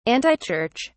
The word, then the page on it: antichurch: British English